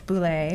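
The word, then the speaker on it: Bouley: David Boulay.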